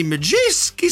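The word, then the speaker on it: Madejski: So, let's head over to a bush just outside the Majewski Stadium in Reading. And our man in the bush, on the ground. Hello. – Hello there. That was an immaculate pronunciation of Majewski.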